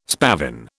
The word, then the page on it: spavin: British English